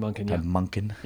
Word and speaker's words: Monken: Todd Monken.